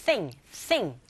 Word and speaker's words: nothing: TH 발음하실 때 sing이 아니고 thing, thing 긁어주시고요.